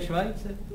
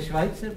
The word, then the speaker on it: Schweitzer: No to se vyslovuje Švajcer. – Co? – To je Švajcer právě.